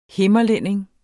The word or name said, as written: himmerlænding